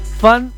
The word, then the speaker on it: Fun: Fan Factory. – Fan Factory. – Se escribe Fun, así sí. Como escuchas. Fun.